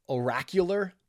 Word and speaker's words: oracular: This is what is called a uh, oracular prayer, O R A C U L A R, oracle. Oracular is the pronunciation of it.